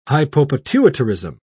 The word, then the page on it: hypopituitarism: British English